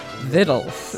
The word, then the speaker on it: Victuals: And her book is called, it looks like Victuals, but it's pronounced Vittles in the mountains.